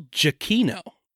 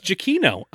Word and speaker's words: Giacchino: We've been pronouncing this name wrong since episode 4, and we need to fix it. – What was episode 4? – The Incredibles. – Okay. – It's Michael Giacchino. – Yeah, Giacchino. – It's Giacchino. It's actually, he has a pronunciation written in his Twitter bio, I found out today.